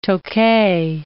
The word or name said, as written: Tokay